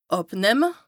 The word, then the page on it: àbnëmme: Bas-Rhin; Strasbourg